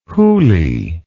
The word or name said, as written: hooly